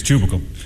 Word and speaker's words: tubercle: Yes, tubicle. – Which way do you spell it? Is it C-U-B-I-C-A-L or C-L-E? – Tubicle, man, not cubicle. T-U-B-E-R-C-L-E. – Oh, tubercle.